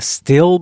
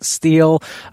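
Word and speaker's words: Stihl: I got to visit the Still booth, and yes, it's pronounced still, not steel.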